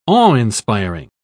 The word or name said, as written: awe-inspiring